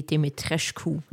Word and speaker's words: Dimitrescu: Ich möchte hier einhaken. Es heißt Lady Dimitrescu.